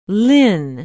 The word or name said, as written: lynne